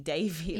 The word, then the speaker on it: Devi: Devi, like, yeah, almost like D-A, and it's more of a D-H, like Devi.